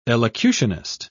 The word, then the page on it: elocutionist: British English